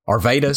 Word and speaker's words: Arvydas: Arvydas Sabonis, the big Sabonis, Sabonis. – Yeah, I can't remember his first name, but yeah. Arvydas.